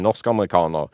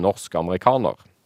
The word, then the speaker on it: norsk-amerikaner: Men nå har jo sikkert lytterne merka at det er en forskjell når jeg sier norsk-amerikaner, og når jeg sier norsk-amerikaner.